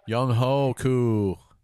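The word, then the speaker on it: Younghoe Koo: And the kicker, Young Kung Koo. – Oh, did I say that right? – Young Ho Koo. – There you go.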